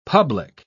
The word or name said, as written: public